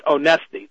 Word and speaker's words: Onesti: That's Onesti. That's the name. Onesti. O-N-E-S-T-I.